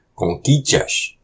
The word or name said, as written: Kôkicash!